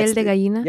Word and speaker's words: piel de gallina: Yeah, piel de gallina? – Yes. Wait, you said. – Pierda gallina? – Piel de gallina.